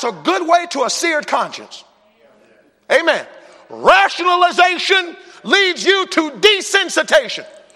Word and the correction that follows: desensitization: Desensitization. Excuse me. Forgot a syllable in there, desensitization.